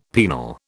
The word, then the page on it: penal: British English